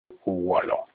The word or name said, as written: uwolo